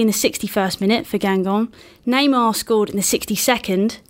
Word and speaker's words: Guingamp: They are knocked out of the Coupe de la Ligue by Guingamp. Have I said that right, everyone? – Yeah. – Yes.